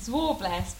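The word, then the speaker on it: Zwartbles: Well, it's pronounced Zwarbless, but it's Zwartbless. Some people call them Zwarbles. Some people call them Zwartbless. I think it's got a silent T, to be correct.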